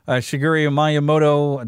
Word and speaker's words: Shigeru Miyamoto: Shigeru Miyamoto.